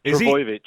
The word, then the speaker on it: Trbojevic: Trbojevic.